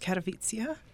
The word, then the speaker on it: Katowice: It's actually pronounced Katowice. It looks like Katowice. – Nice. – To English speakers.